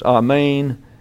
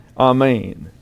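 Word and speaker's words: amen (ἀμήν): You'll note the E has a line over it with an accent. That means it's an eta, not an epsilon. And so an eta gets the sound of a long A in English. So it's amen, amen.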